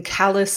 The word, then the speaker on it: Calais: I grew up in Calais, Maine, spelled like the Calais in France, but said like the thing on the bottom of your foot.